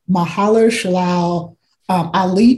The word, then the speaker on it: Mahershala Ali: I always say his name wrong, but I'm going to say it right. Mahershala Ali become Blade.